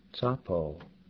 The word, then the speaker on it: tsapo: Tsa is the ts at the end of the word grits, plus the addition of the ah sound. Tsapo, hot.